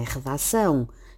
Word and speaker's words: redação: Na verdade, o til só assinala a sílaba tónica quando recai sobre a última sílaba de uma palavra, como acontece, por exemplo, em redação, na qual são é a sílaba tónica, sendo esta palavra aguda ou oxítona.